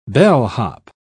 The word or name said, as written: bellhop